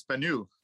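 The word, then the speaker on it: Banneux: Am I pronouncing that correctly? – You're pretty close. It's Banneau.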